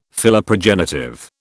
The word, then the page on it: Philoprogenitive: British English